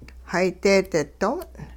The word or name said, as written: hay deedi do'ong